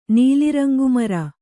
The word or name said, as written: ನೀಲಿರಂಗುಮರ